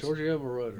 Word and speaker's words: Giorgio Moroder: Giorgio, and I cannot pronounce Giorgio Moroder.